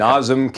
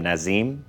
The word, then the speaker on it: Nazem: By the way, Johnny Lyles told me that his nickname, they mispronounced his name in Toronto for like 6 months. They called him Nazim.